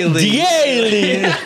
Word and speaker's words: Diele: How do you say it? – Diele. – I was like, Daily.